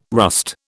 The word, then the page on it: rust: British English